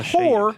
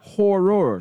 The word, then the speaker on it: Horror: Not the whore, shade. Horror. – Horror. Horror. – Two syllables.